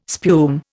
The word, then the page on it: spume: Indian